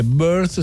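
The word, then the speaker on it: Birthh: Little Red, lei è birth, si pronuncia come la parola nascita in inglese, ma è scritto con una doppia H, che è un vezzo che deriva dal fatto che, come dire, l'ha voluto aggiungere per comunque rendere il nome unico, no?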